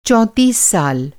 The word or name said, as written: चौंतीस